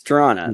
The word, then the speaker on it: Toronto: Yeah, it's Toronto. There's no second T in that.